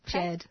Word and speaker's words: Djed: Um, well, she's the founder and of the publication Dej Jed. Jed. That's okay. Literally everybody Jed. makes that mistake. You know I'm why? thinking of changing the name. For our listeners, it's spelled D J E D.